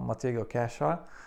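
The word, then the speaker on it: Matty Cash: Kiedy to się stało, bardzo wiele osób dyskutowało na ten temat jak należy wymawiać jego nazwisko. Chodzi o Matiego Casha. I niektórzy upierali się, że to jest A, a inni, że E. No, prawda jest taka, że to nie jest ani polskie A, ani polskie E, tylko e, jest to samogłoska, która po prostu nie istnieje w języku polskim.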